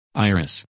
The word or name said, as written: Iris